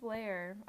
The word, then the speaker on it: Lehr: And also in 1968, Steve Lehr, I think that's how you pronounce it, L-E-H-R, Lehr.